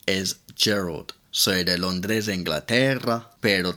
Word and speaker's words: Gerald: y hay un nombre para las chicas que es Geraldine, así que es como Geraldine, pero sin in, o sea, Gerald. Pero en inglés se pronuncia Gerald.